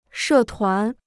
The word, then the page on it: 社团: shè tuán